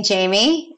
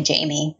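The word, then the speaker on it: Jaime: And with my name being Jamie, I wanted to go away from actually having my name in my domain because there's so many different ways to spell it.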